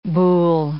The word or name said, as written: boulle